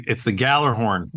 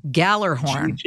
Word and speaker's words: Gjallarhorn: You know, how do you pronounce that horn? – It's the Gjallarhorn. – Gjallarhorn. – Gjall. So that's how you that's Gjallarhorn.